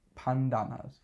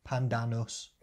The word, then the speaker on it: Pandanus: Pandanus. – That's how it's spelled. Pan, P-A-N, and then D-A-N-U-S. Pandanus.